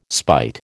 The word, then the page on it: spite: American English